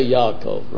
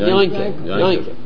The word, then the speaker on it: Yaakov: I'm thinking of, for example, we say Yaakov, right? – Yeah. – Yeah. But how the old-fashioned Litvox? How did they pronounce it? – Yeah. – They pronounce it Yanke.